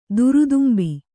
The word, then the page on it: ದುರುದುಂಬೆ: kannada